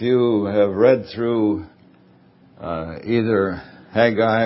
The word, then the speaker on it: Haggai: Sometimes I've said Haggai, but I think proper pronunciation is Haggai.